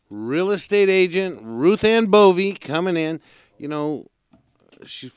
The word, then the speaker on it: Bowe: But she spells her name B O W E. But it's pronounced B Bovey. B O W, and it's pronounced Bovey.